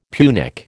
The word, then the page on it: Punic: American English